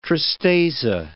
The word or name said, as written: tristeza